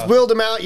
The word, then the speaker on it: Wildemount: That is Wildemount. Y'all can suck a dick. – Go at Matt Mercer. – The E is silent. – It's Wildemount.